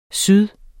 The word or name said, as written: syd